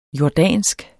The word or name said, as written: jordansk